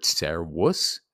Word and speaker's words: servus: This comes from the Latin word servus, spelt servus. Remember, these are always pronounced as W's. Servus.